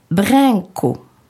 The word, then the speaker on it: branco: A-I-N. France s'écrit France et se prononce France. Blanc s'écrit branco et se prononce brinco. Plante s'écrit planta. Donc plante P-L-A-N, brinco, B-R-A-N. Mais on prononce A-I-N.